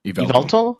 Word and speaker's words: Yveltal: Definitely the two legendaries, and I'm going to mispronounce them, Xerneas and Yveltal.